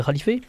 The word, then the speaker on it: Khalifé: On peut dire, non, c'est plutôt les khalifés. On prononce comme ça, je crois.